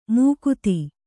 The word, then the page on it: ಮುಕುತಿ: Kannada